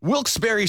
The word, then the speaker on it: Wilkes-Barre: Wilkes-Barre Scranton's 97.9X.